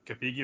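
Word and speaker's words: Gathegi: Cathagi, that's how you say his name.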